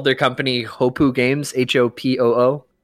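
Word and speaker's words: Hopoo: Hopu. It sounds like it's spelled.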